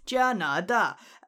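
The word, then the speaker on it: Janada (加拿大): Canada is apparently Janada, and not first tone for the na there.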